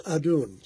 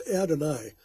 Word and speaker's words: Adon: Today's word is Adon, sometimes pronounced Adonai.